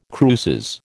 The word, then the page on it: cruces: American English